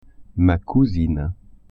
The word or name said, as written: cousine